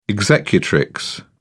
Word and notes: executrix: British English

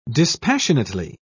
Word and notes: dispassionately: British English

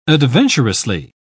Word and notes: adventurously: British English

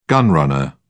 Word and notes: gun-runner: British English

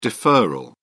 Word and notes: deferral: British English

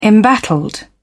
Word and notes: embattled: British English

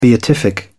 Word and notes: beatific: British English

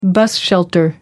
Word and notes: bus shelter: American English

